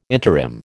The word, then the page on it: interim: American English